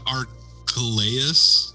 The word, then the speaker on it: Archelaus: Yeah, Archelaus is how I would pronounce it.